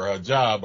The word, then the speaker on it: hijab: But then last night I came across a broad in a hijab. Or hijab, or however you pronounce it, right? – Hijab.